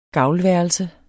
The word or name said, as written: gavlværelse